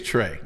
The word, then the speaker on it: Tres: My name is spelled T-R-E-S. That's very good. Got to keep up with that. That's the part. Most people mess that up. And yes, it is Trey. This is just solid. Okay. So T-R-E-S Gilbert.